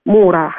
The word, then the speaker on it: Мура: Мура. Хотя да. ударение, конечно, нужно переставить, по-моему, на второй слог.